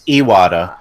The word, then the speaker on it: Iwata: Isn't that the correct pronunciation? No, it is. – It's Iwata.